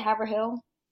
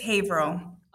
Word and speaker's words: Haverhill: Did I say that right, Haverhill? – It's Haverhill. – Okay, Haverhill.